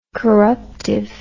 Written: corruptive

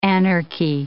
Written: anarchy

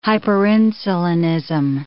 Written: hyperinsulinism

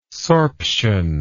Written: sorption

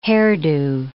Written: hairdo

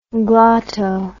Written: glotto

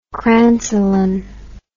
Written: crancelin

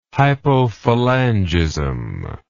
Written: hypophalangism

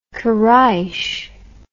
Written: koreish

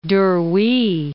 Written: Duruy

